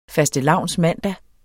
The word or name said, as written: fastelavnsmandag